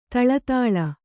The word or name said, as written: ಥಳಥಳ